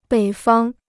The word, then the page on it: 北方: běi fāng